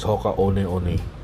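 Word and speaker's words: Hoka One One: The proper pronunciation is Hoka One-One. One-One is like a Hawaiian term for fly, okay?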